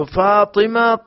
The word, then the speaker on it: فاطمہ: تو فاطمہ پا کے زیر کے ساتھ ہے اور اس کے معنی یہ ہے کہ وہ عورت جس سے اس کے بچے کا دودھ چھڑا دیا گیا ہو.